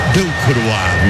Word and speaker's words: Delcroix: Tu perde o zagueiro, camisa 22, perde a bola no meio, deu cross, deu croar, é francês, é pronúncia francesa que Realizuba me ensinou. Delcroix, monsieur.